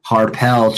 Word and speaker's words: Harpel: Let's bring him on here all the way from Missouri, just west of St. Louis, our boy Trey Harpel.